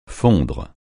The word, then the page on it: fondre: francais